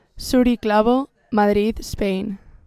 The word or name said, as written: Zuri Clavo